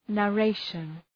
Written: narration

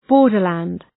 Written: borderland

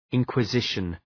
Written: inquisition

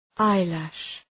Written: eyelash

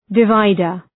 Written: divider

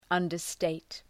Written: understate

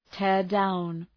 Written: tear down

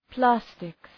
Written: plastics

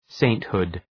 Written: sainthood

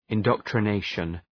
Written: indoctrination

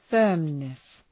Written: firmness

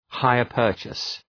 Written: hire-purchase

